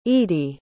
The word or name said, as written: eady